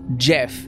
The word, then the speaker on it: Gef: the creature introduces itself as Jeff, spelled G-E-F.